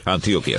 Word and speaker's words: Antioquia: Medellín es capital del departamento de Antioquia, ¿no? ¿Se dice así? – Antioquia, sí.